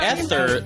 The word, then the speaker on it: Aether: No, they call it the Aether. They call it the Aether in Thor the Dark World, but it is spelled Aether, A-E-T-H-E-R.